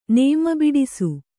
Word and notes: ನೇಮಬಿಡಿಸು: nēma biḍisu; dial.

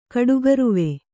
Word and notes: ಕಡುಗರುವ: kaḍugaruva

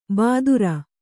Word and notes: ಬಾದುರ: Kannada

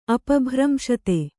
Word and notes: ಅಪಭ್ರಂಶತೆ: apabhramśate

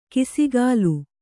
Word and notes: ಕಿಸಿಗಾಲು: kisigālu

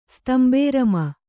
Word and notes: ಸ್ತಂಬೇರಮ: kannada

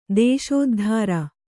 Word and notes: ದೇಶೋದ್ಧಾರ: Kannada

